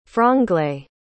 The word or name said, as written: franglais